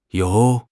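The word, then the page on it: you2: tone 2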